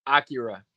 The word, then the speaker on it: Akira: Do you pronounce it Stelkira? Is that how you pronounce it? – Yeah, Akira. – Akira, okay. Yeah, Stelkira, like Akira.